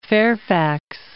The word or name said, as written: Fairfax